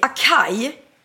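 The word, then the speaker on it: açaí: Och då uttalar jag det acai. Och jag gör ju helt fel. Jag gör en gillis. – Ja. – Det ska uttalas acai.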